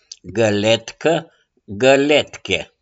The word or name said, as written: GĂLETCĂ